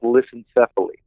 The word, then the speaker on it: lissencephaly: Yeah, in simple terms, it's called smooth brain, but in medical terms, it's called lissencephaly.